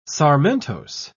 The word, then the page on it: sarmentose: British English